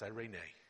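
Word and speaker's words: irene (εἰρήνη): It's interesting that every time I look at the word transliterated into the English, it is tough for me to remember that it's pronounced irene.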